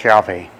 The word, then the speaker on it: Yahweh: We think most likely now it was pronounced something like Yahweh.